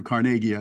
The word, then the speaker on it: Carnegie: Um, Andrew Carnegie, I should say, to pronounce him as his Scottish way, not not in his American.